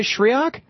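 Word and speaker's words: Schriock: And Stephanie, am I saying this right? Shriok? – Stephanie Shriok. You got it.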